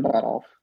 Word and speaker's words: Buttolph: Yeah, so Stephen Buttolph. Yeah, it's like Rudolph, but with a butt in front of it.